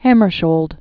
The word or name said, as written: Dag Hjalmar Agne Carl Hammarskjold